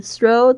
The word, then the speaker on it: throw: Then throw the tissue in the trash throw, T-H-R-O-W, 特别注意一下 TH 的发音的声音，舌尖两排牙齿轻轻咬住 throw the tissue, T-I-S-S-U-E, tissue 纸巾或面纸 in the trash 就是丢到垃圾桶里。